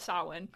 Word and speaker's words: Samhain: I think a lot of people I've heard this pronounce Samhain. Ooh. That's how it's spelled, but that's not how it fucking is. It is, I'm going to call it Samhain.